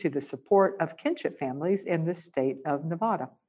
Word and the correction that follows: Nevada: Or actually, I said that wrong. I should say the state of Nevada. As a Southerner, I can't help but always mispronounce that.